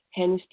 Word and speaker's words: Hanne: Well, I do classes all over the world all the time, and you can find me on Facebook, Hanne Stigard, and my name is spelled H-A-N-N-E. Stigard is spelled S-T-I-G-A-A-R-D.